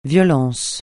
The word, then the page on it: violence: francais